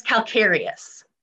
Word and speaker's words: calcareous: I'm not sure I'm saying this right, but calcareous fens, is that the proper pronunciation? – Your Honor, I believe it's calcareous.